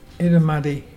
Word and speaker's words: Irrumardi: Itamuddy.